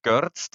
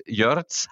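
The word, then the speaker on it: Görtz: wie ich sag nur Max Görz, der in Wirklichkeit Jörz heißt, ja.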